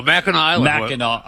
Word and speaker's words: Mackinac: Oh, no, Mackinac Island. First of all, It's spelled, why do they spell it Mackinac when it's Mackinac?